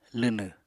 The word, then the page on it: lwnw: apatani